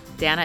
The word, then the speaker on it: Dannah: Dana is spelled like Hannah, D-A-N-N-A-H.